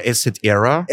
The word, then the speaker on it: Assetera: Ja, herzlich willkommen, ähm, CEO und Founder von Assetera. Sprich ich das richtig aus? – Oder Asset Era? – Asset Era. – Es, es gibt mehrere Möglichkeiten. Wenn man es in Italienischen aussprechen würde, wäre es Asset Era.